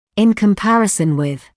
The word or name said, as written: in comparison with